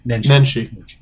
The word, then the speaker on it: Nenshi: And Nenshi, how do I say his name? – Nenshi. – Thank you, Nenshi. As soon as I said Nishi, I thought I screwed that up.